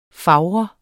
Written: fager

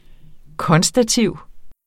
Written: konstativ

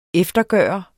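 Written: EFTERGØRES

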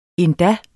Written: endda

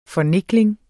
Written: fornikling

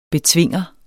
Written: betvinger

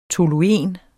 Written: toluen

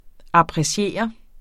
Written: appreciere

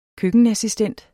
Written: køkkenassistent

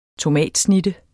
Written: tomatsnitte

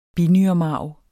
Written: binyremarv

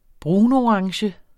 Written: brunorange

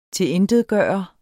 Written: TILINTETGJORTE

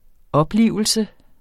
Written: oplivelse

